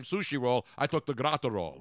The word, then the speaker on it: Graterol: Now, according to Hitman 17, Brusdar Grat—I got it. See, I'm good with Spanish pronunciation. – Brusdar Graterol. – Graterol.